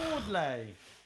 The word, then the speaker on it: Wardley: It's got a Y on the end. – Oh, sure. – There we go. Wardle. – There you go. – Wardley.